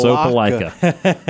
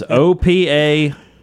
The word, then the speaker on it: Opa-locka: No, I did not mispronounce Opa-locka. It is Opa-locka, Opa-locka, Florida, Miami Norland High School.